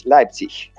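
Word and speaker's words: Leipzig: А, а другая, не, правильно произносить, как говорит Сергей Кривокарчик, э, Лейпц, Лайпциг.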